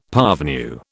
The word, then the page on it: parvenu: British English